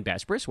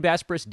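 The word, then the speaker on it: Besbris: I'm Dan Bespris. Welcome to the show. I hope you guys have been doing what I requested and finding me on social media. It's at Dan Bespris, D-A-N-B-E-S-B-R-I-S.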